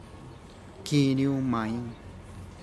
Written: kinu mine

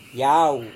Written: yau

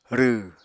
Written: rw

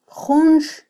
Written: junx

